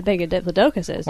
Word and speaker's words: Diplodocus: There's a picture of an elephant here compared to the size of a Diplodocus. Is that how you pronounce it? – Diplodocus. – A Diplodocus, yes.